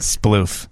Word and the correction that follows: sploot: Splooting. – Sploot.